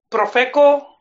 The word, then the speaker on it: Profeco: With Chivas started getting all these from the Profeco. Am I saying it right? – Yeah.